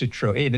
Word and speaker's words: Citroën: But yes, pronounced Citroën.